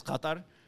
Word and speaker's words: Qatar: So it's like, okay, so there's a lot of, so in like Arabic, the proper pronunciation is Qatar.